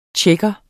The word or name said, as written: tjekke